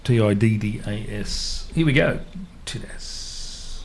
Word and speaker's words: Tiddas: Tidass. – T-I-D-D-A-S.